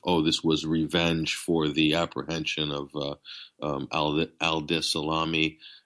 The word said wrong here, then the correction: Salah Abdeslam: I can't even pronounce his name correctly. I'm not sure. – No, you're close enough. It's Salah Abdeslam.